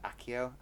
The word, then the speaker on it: Accio: No, so to summon something, now I'm not sure about the pronunciation. It's A-C-C-I-O, so it's like Accio, Accio. Then you say what you want.